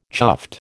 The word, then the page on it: chuffed: American English